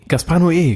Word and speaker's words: Gaspar Noé: Gaspar Noé. – Gaspar Noé, genau.